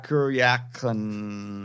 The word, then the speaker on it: Kuryakin: And it's Napoleon Solo and Ilya Kuryakin.